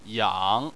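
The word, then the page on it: yang: tone 3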